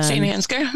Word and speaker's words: St. Ansgar: Correct me if I'm not saying this correctly, but Ansgar Ansgar, uh-huh. Iowa. That's where I was at. Um, St. Ansgar. Mm-hmm. Yeah. – Yep. That was it.